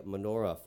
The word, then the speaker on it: menorah: And finally, lighting the menorah for 8 straight nights. – Machnocha. – Menorah. – That is the pronunciation right there.